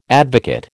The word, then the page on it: advocate: American English